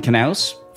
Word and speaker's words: Knaus: Thank you, sure Dustin Knaus, for insisting that we pronounce the K.